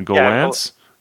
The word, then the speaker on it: Gollancz: Gollancz.